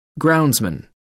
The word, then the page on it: groundsman: American English